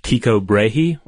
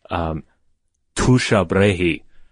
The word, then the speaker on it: Tycho Brahe: And that is one Tycho Brahe, or if you go with some pronunciations, Tusha Brahe, which I'm not going to use.